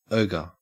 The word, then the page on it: ogre: Australian English